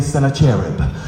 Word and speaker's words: Sennacherib: Some pronounce it Sennacherib, some say Sennacherib.